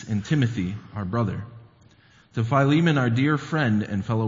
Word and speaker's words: Philemon: I know that some people pronounce it differently. My New Testament prof kind of hammered it in my head that that's how it's pronounced, Philemon.